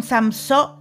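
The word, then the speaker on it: Samsó: que es El Código del Dinero de Raymond Samso, así, con acento en la última O.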